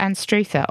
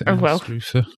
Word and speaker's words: Anstruther: And we have had a message from Alex and his family who have recommended the fish and chips at Anstruther on the Firth of Forth.